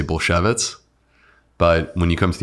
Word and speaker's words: Bulsiewicz: Bulsiewicz. – Bulsiewicz.